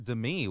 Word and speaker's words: Demi: However you pronounce her first name, Moore, is turning 55. – Yeah, Demi, that's right.